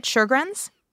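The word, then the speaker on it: Sjögren's: Am I saying it right? Sjogren's? – Mm-hmm.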